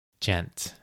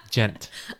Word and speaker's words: Djent: D-J-E-N-T. – Wait, what? There's a silent J? – Silent D, dude. – Oh. – Djent. – I couldn't tell if you were saying dent or djent. – Djent. Like gentleman, djent.